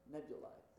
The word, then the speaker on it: nebulae: The astronomers called them nebulae. Am I saying that right? Nebulae. Thank you for your astronomer. Nebulae.